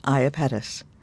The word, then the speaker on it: Iapetus: Iapetus, pronounced Iapetus.